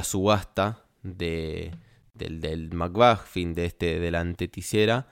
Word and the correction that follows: Anticitera: eh, anticitera, perdón, lo, pronuncié mal,